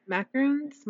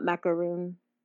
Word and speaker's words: macaron: How about, like, macarons? Macarons? I don't know how to say it. – Oh, okay. So it is, it's a macaron. – Okay. – So it's not macaroon,